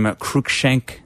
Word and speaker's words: Cruikshank: Cruikshank. – Cruikshank.